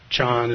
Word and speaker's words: Chan: Chan is the Chinese pronunciation of the word that's pronounced Zen in Japan.